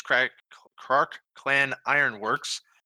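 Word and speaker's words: Krark-Clan Ironworks: And for Tark, it is Krark Clan Ironworks. Am I saying that right? – Clan Ironworks, yep.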